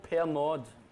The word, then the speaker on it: Pernod: And it would be called pernod here, but it's silent D, pernil yes.